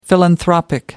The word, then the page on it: philanthropic: American English